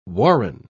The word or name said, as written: warren